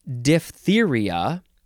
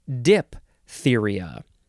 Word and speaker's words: diphtheria: The official pronunciation is diphtheria, but a common, not entirely correct pronunciation is diphtheria. And it is spelled, of course, D-I-P-H-T-H-E-R-I-A. And the P-H makes an F sound, so it is officially pronounced diphtheria.